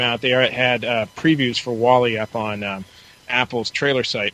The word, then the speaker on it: WALL-E: What do you know about this movie, Wall-E? Is that how you pronounce it? – Yeah, it's Wall-E.